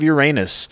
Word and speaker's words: Uranus: town of Uranus, which is pronounced the way any self-respecting class clown would say it.